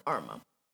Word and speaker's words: Armah: Sen kommer man till Arma. Alltså, jag, man vet inte hur många gånger jag har hört arm. Så arma. Arm, arm, ja.